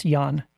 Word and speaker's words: Jan: And, the author's name is Jan Hettinga and it's J-A-N. So it looks like Jan, but it's a man. So it's Jan. It's pronounced Jan. – I'll allow it. – Jan or Jan. Hettinga, H-E-T-T-I-N-G.